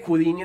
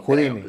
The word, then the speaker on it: Houdini: Yo creo que sí es, pues, Houdini, güey. – Houdini, sí, sí. – ¿Houdini o Houdini era? – Pues creo que Houdini es la forma correcta de decirlo. – Houdini.